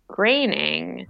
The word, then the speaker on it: Groening: And it says, you know, it's spelled G-R-O-E-N-I-N-G. And then it says rhymes with complaining. And so I guess I always was like, oh, I guess that means it's graining.